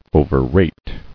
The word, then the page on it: Overrate: o·ver·rate